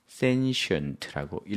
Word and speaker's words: sentient: 이때 T라고 읽지 않고 sentient라고 읽어야 되겠죠.